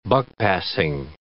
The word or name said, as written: buck-passing